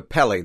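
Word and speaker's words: Kpelle: Well, you decide that Oprah is probably from a West African ethnic group known as the Kapele. Did I say that right? – It's the Pele. The K is silent. It's K-P-E-L-L-E, and they're from Liberia.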